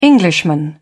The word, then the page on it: englishman: British English